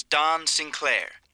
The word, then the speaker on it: Don Sinclair: This is Don Sinclair. – How do you spell that? – D-O-N, then new word, Sinclair. S-I-N, C-L-A-I-R.